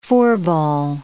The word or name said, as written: four-ball